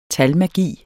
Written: talmagi

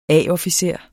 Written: A-officer

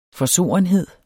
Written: forsorenhed

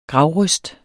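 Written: GRAVRØST

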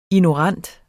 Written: ignorant

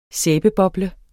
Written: sæbe

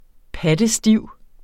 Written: pattestiv

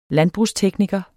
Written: landbrugstekniker

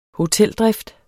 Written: hoteldrift

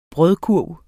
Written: brødkurv

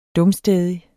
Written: dumstædig

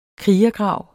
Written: krigergrav

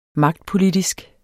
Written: magtpolitisk

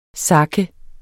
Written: SAKE

